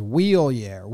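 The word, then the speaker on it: Wilyer: Is it Willier? Is it Willyer? – Prospect season. – Do you pronounce it Willier? – I don't know how else you'd... – Willier? Willier is... Baseball everyone says Willier.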